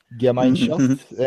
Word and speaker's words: Gemeinschaft: Gemeinschaft ve Gesellschaft galiba böyle okunuyor Almanca'da. – Gemeinschaft and Gesellschaft. Doğru.